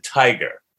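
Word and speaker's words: Tigar: I do believe Ken pronounces it tiger. So he does pronounce it like the animal.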